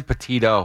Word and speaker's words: Petito: Gabby Petrino? How do you say her name? – It's Petito. – Petito. Gabby Petito.